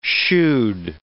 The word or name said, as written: shoed